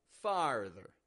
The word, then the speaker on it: farther: As I pointed out, when we got that R, R, we're opening our mouth up, getting a clear ah sound, lax A, followed by an R, far, and then ther, ther, going flat on that ending, ther, farther.